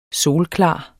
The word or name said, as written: solklar